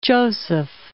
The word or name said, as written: Francis Joseph I